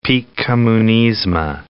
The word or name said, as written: Pik Kommunizma